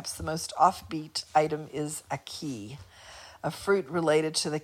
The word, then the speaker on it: ackee: Aki. – Yep, I was close. Aki.